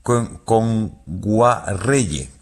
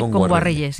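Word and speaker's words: Kngwarreye: Emily Kame Nguagyerre, lo he dicho mal. – Emily Kame. – Con Guarreye. – Emily Kame con Guarreye, sí.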